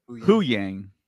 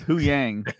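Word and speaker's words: Huyang: I did like Yu-Hong. How do you pronounce it again? – Hu-Yang. – Hu-Yang. – Instead of Hu-Dat, it's Hu-Yang.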